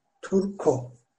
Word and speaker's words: Turco: Turko. Yazılışı da Trabzon, Uşak, Rize. K harfimiz yoktu. K ile C kullanıyoruz kalın ünlülerde. C o. Turco. Fakat okunuşu Turko.